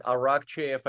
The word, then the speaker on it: Arakcheyev: Yeah, listen, I'm all over social media, Vlad Arakcheyev.